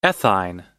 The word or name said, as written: ethyne